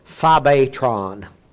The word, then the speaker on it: phobetron: This is the phobetron. P-H-O-B-E, long E, T-R-O-N.